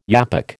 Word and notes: yapok: American English

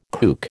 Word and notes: kook: American English

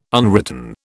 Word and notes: unwritten: British English

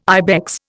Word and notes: ibex: Indian

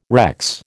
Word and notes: recce: American English

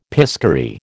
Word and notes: piscary: American English

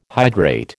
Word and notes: hydrate: American English

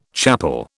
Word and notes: chapel: British English

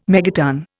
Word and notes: megaton: Indian

